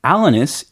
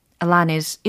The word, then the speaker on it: Alanis: 미국 발음 원래 Alanis. – Alanis. 어. 알겠습니다. – 어. 미국식으로 Alanis.